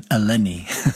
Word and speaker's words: Eleni: I'm used to the Greek pronunciation, Eleni.